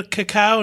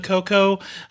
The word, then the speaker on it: cacao: I'm going to say creme de cacao, not creme de coco. I did a lot of searching around on the correct pronunciation, and I'm just going to go with Jacques Torres, who says cocoa means nothing to me. So it's cacao for here on out.